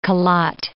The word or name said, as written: Khelat